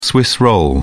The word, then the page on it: swiss roll: British English